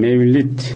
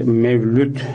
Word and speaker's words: Mevlid: Şimdi efendim, Mevlid ki Mevlüt şeklinde de telaffuz edilir. Ama bizde yaygın olarak Mevlid şeklinde telaffuzu son zamanlarda daha yaygındır.